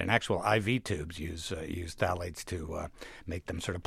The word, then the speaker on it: phthalates: I don't even know how to pronounce this. It's P-T-H-A-L-A-T-E-S. Why don't you pronounce it for us? Tell us what it is and what it's in. – Yeah, this is an incredible substance called phthalates.